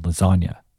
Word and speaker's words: lasagna: Of course, Wayne adds his clever spin on this classic hip hop trope by comparing the silence of G's to the silent G in the word lasagna.